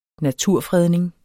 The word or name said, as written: naturfredning